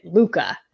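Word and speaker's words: Lykke: It's called The Little Book of, you say it, Luca is how you say it.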